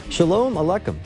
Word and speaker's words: Shalom Aleichem: Shalom Aleichem. Did I say that right, Tom? How's my Hebrew? – I think it's great.